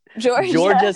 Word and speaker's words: Jorja: How do you say her name? What is it? – Georgia Smith. It's spelled weird, but it's cool.